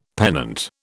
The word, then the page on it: pennant: British English